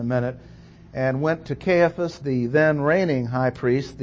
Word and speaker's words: Caiaphas: Get his name right. C-A-I-A-P-H-A-S, Caiaphas next.